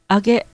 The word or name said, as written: age